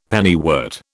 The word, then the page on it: pennywort: British English